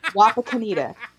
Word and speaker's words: Wapakoneta: I compromised by training myself to say waffle cone eater. Wapakoneta.